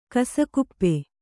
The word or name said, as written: ಕಸಕುಪ್ಪೆ